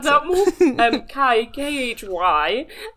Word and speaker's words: Khy: Kai. – Kai. – It's spelled Kai. It's spelled K-Y-H. So just the K-H-Y. – B-I-C-T-H.